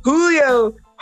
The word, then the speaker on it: Julio: Well, let's Julio Jones.